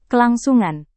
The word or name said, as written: kelangsungan